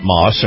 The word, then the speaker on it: Maus: Moss is spelled M-A-U-S.